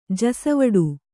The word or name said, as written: ಜಸವಡೆ